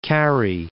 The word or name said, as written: Carrhae